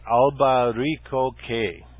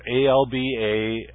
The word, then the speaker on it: albaricoque: And by the way, the name of the tree and plant is albaricoque, and that's not pronounced right. It's kind of the English pronunciation. It's albaricoque.